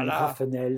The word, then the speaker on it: Yannig Raffenel: Est-ce que je prononce bien ton nom de famille déjà? – Ouais. Voilà. Yannig Raffenel, c'est très bien, c'est comme Raffenel.